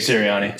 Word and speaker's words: Sirianni: Nick Sirianni.